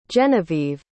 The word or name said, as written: Jenevieve